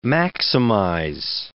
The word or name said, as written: maximize